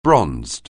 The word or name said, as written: bronzed